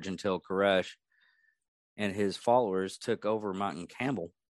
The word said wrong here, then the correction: Carmel: Is that camel or camel? I think it's camel. I'm saying this right every time. Oh, Carmel.